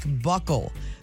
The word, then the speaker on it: buccal: Thursday tidbit, B-U-C-C-A-L, which you talked about in Randos, is pronounced like buckle because the ancient Romans had their helmet straps fastened at the cheek.